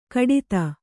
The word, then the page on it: ಘಟಿತ: ghaṭita